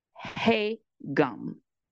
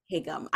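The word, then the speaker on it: Hae-gum (해금): Okay, so Dongyi is now a palace servant, and she plays an instrument called the Lo had to help me out with this pronunciation. I'm probably still going to butcher it, but Hae-gum. – Hae-gum. I would say the same thing. So my pronunciation is no better, just so you know. But I would say the same thing. Hae-gum.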